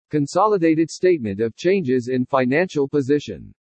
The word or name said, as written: CONSOLIDATED STATEMENT OF CHANGES IN FINANCIAL POSITION